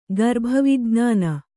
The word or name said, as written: ಗರ್ಭವಿಜ್ಞಾನ